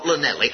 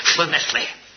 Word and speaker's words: Llanelli: Make sure you pronounce it correctly. Not Llanelli, Llanelli.